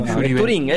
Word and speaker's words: Venturin: Con acento en la I último. Venturin, es porque Yuri es de Sarandí.